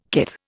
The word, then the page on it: kith: Indian